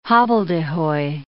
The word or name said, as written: hobbledehoy